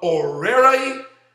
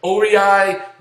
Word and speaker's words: Owerri: So then we began to talk to Pastor Chime One from Orerai, Nigeria. I'm making sure I say it right. O-W-E-R-R-I, Nigeria. Orerai, Nigeria, Pastor Chime One.